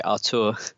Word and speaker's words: Arthur: Yeah, i I, find that claim by Gremio somewhat surprising because I think Arthur was one of those, or sorry, Artur, get the pronunciation right.